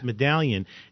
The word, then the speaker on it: Madalian: So Dr. Medallion, it's spelled M-A-D-A-L-I-A-N, but it's pronounced Medallion.